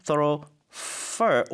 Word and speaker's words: thoroughfare: The main thoroughfare or fair. 이게 발음이 F-A-R-E가 영국 쪽은 fair 그리고 미국 쪽은 fur로 발음을 하더라고요.